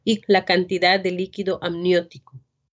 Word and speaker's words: amniótico: And you can hardly hear the last syllable there. It's líquido amniótico.